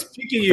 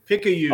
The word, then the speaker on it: Picayune: Like, I'm just going to ask Jeff to come in and describe the P-I-C-A-Y-U-N-E. Jeff, I'm really sorry. I'm having a really, really bad night with pronunciation. But it's Picayune. – Very welcome. – Picayune.